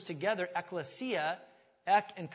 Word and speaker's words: ekklesia: If you want to just phonetically write it out, E-K-K-L-E-S-I-A, ekklesia.